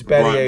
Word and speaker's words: Battier: It's Battier.